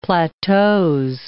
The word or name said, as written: plateaux